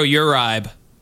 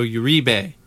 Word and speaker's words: Uribe: Is Pedro Uribe. Pedro Uribe. – I don't know what you're smoking, because that's not how you pronounce that at all. It's Pedro Uribe.